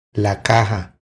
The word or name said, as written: La caja